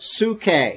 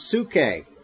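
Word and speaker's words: suke (ψυχή): It's pronounced suke.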